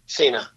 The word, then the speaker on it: Sanaa: Yemen is Sanaa. How do you okay. – Is that how you pronounce it? – Sina. – Okay.